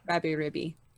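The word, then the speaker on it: Rabi-Ribi: I finally played a side-scroller platformer called Rabby Ribby. And how the fuck do you spell that? – R-A-B-I hyphen R-I-B-I. Rabby Ribby.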